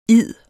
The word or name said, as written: id